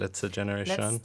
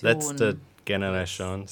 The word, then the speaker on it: Letzte Generation: How do I say it? Letzte Generation. Letzte Generation. – Letzte Generation. Sorry, I sang bad.